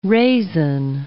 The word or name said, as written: raisin